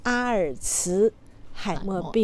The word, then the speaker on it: 阿尔茨海默: 因为他是德国人，所以我们按照德语发音来翻译这个病名，所以读作阿尔茨海默病。